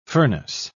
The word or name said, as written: furnace